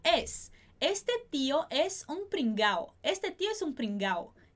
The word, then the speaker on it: pringado: Se a gente realmente pronunciar o D, ficaria este tio é um pringado. Perfeito? Como é que vai ficar a pronúncia dessa frase? Este tio é um pringao. Tá bom? Sem essa pronúncia do D.